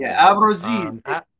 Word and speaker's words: Abruzzese: uh was it how do you pronounce his name? Abruzzi, uh, Abruzzese. – Nick Abruzzese.